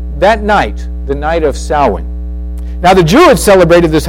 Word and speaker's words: Samhain: It's spelled S-A-M-H-A-I-N, but it's pronounced Samhain, which is October 31st.